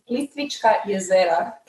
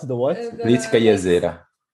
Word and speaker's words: Plitvička jezera: And one more location that Guillermo visited is Plitvička jezera. Can you try to pronounce it one more time? – Wait, the what? – Plitvička jezera. – Oh, the lake. Oh, Plivice. I just say Plivice.